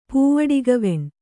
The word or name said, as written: ಪೂವಡಿಗವೆಣ್